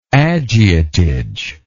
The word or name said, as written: agiotage